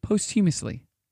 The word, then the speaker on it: posthumously: After the death of a person in question, Sam was honored for his charity work posthumously. – Oh, wait, posthumously. – That's how you say it? – Posthumously, yeah.